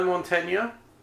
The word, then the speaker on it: Montagna: Well done for pronouncing that name correctly. Every commentator in the country. I know you won't need to call it anymore. But it's not fucking Montagna. It's a soft G sound.